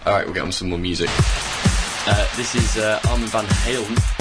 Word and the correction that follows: Armand van Helden: Go on, Tim, you can pronounce it. – Armand van Helden. – Thank you. Not Van Halen. Apparently that was a different band.